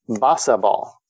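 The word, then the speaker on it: Bossaball: So boss a ball. Okay. It, that is how it's spelled. So it sounds like a boss and it's a ball there.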